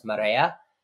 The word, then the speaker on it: Marea: It's actually pronounced Marea which means it's Spanish for the tide.